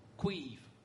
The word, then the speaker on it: Caimh: No, my name is spelt C-A-I-M-H. – And pronounced? – Queeve.